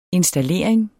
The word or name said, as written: installering